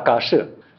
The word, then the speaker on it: 迦叶: 啊，下一个，这个头陀第一的大迦叶，应该念大嘎舍，这两个字嘛，在唐代以前嘛，都读作嘎舍，现在读作迦叶。